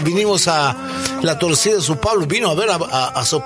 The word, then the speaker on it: São Paulo: Dice São Paulo.